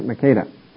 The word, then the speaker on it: Makkedah: That's the proper way to say it, Makkedah.